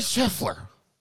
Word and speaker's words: Scheffler: Scotty Scheffler.